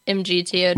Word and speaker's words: MGTOW: Is it MGTOW? How do you say it? Men going their own way? – Oh, yeah, MGTOW.